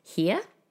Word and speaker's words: hir: We've got a new her, a new hey, it's actually H I R and it's pronounced here, not to be confused with come here, H E R E.